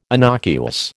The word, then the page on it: innocuous: American English